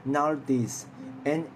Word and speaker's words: Nauthiz: 这个卡的话呢，呃，它放这个这个 pronounce 的话呢，其实是 Nardiz，N A U T H I Z，Nardiz，OK。